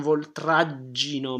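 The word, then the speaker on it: v'oltraggino: Non voltraggino mai. In this case we have double G, so it means short A before.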